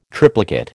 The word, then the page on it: triplicate: American English